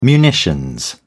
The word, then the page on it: munitions: British English